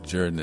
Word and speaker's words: Jordan: And after singing the piece over and over and over again, you know, Jordan is the correct pronunciation.